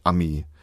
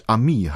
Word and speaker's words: Ami: Einerseits haben sie die Betonung ihrer üblichen Aussprache auf der ersten Silbe angepasst und aus dem Ami einen Ami gemacht, und dann haben sie auch noch die Bedeutung verändert und den Freund in einen abscheulichen Kerl abgewandelt.